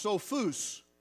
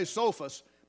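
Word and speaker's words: Sophos (σοφός): In the Greek language, it's pronounced Sophos, with the emphasis on the last syllable. In English, we say Sophos, but it's spelt the exact same way.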